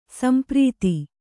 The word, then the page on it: ಸಂಪ್ರೀತ: Kannada